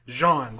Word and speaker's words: Jean: Yeah, he's from the DR, but his If you see anybody from the DR and their name is Jean, don't fucking say it like that. It's Jean, like Wyclef Jean. You wouldn't say Wyclef Jean.